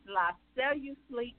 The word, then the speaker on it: La Cellu Sleek: It's La Cellu Sleek Studio. La Cellu Sleek. – And that's spelled LaCellu and then Sleek, SLEEK.